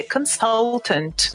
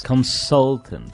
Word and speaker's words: consultant: Então, tem que ser consultant. – Consultant. Consultant. – E não consultant.